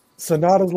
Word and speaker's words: Sanada: You gotta say it right. Ace of the the ace of the cold skulls. Sanada.